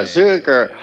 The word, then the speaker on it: Zuker: Half my family pronounces it, no one pronounces it Zucker, but some pronounce it Zooker. And then I pronounce it Zooker because it's just like it's spelled that way, so it was just easier to get people to spell it the right way.